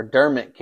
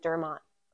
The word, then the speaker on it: Dermot: Check out Dermot Kennedy or Dermot Kennedy's. – No, Dermot. He said he pronounces it Dermot. – I thought it was Dermot, like Kermit.